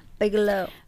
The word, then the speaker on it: Bigelow: Ich mir extra aussprechen lassen. Bigelow.